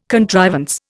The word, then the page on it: contrivance: Indian